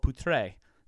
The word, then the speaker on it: Poutre: I think it was a pronunciation, because my grandfather would say Poutre, and he goes, oh, pooped, P-U-T-T-R-E. And that changed the whole pronunciation of it. And they moved into a German neighborhood. So it's Puttry. And really, it's called Poutre.